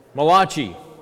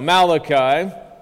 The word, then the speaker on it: Malachi: right, Old Testament reading for today was from the only Italian prophet, Malachi. No, just kidding. That's not how you pronounce it. That's how I heard it, right? Malachi, the only Italian prophet. All right, no, Malachi.